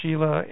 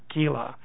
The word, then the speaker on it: Scilli: Sometimes it's pronounced Sheila and sometimes it's Skila. It's S-C-I-L-L-I. Depends on whether you're using ecclesiastical or... – Oh, S-C-I-L-L-I. Okay. – Yeah, or classical Latin.